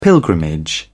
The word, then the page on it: pilgrimage: British English